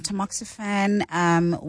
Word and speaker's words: tamoxifen: And then another one says, "Hi Clement and the doctors, I completed the 10 years tamoxifen.